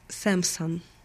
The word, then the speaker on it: Samsung: Если вы захотите поговорить о Samsung, то знайте, что Samsung на английском произносится Samsung.